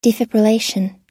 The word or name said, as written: defibrillation